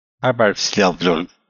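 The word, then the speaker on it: Hárbarðsljóð: Now, remember, it's an F, not a D. – Harbardsljóð.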